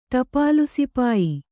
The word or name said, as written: ಟಪಾಲುಸಿಪಾಯಿ